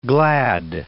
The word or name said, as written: glad